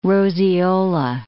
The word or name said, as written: roseola